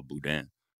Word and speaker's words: Boudin: I always want to pronounce his name like he's like I am suddenly Cajun. But yeah, no, yeah, it's Boudin. I'm just saying,